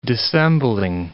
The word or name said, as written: dissembling